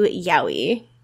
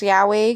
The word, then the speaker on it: yaoi: how likely would Carrie get into yaoi? – I hate that it's pronounced yaoi because I always say yaoi in my head.